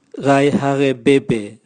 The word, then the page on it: gaihagebebe: Raga